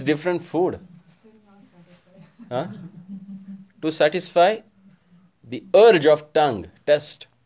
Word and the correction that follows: taste: Taste or test? What do you call? How do you pronounce that? – Taste.